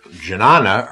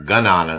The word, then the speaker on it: Jnana: The Indian word janana or ganana, it's sometimes pronounced in English, is a Sanskrit word, which means exactly the same thing, and it is etymologically related to gnosis.